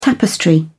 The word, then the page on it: tapestry: British English